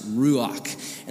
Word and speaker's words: ruach: So that word for spirit, where it says evil, it's ra'ah, and then before that is a spirit, which is ru'ah, and that's actually pronounced ruach.